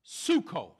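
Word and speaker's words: suko (ψύχω): Oh, by the way, on that term, so again, the Greek word for grow cold is pronounced suko. Say suko. But if you look at the Greek dictionary, the word is spelled, in English, is spelled P-S-Y-C-H-O. Psycho.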